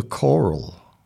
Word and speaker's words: Choral: It's called The Coral, I think. That's how you pronounce it. C-H-O-R-A-L. The Coral. Anyway, it's at Ralston Hall, 5:30 for 6 p.m.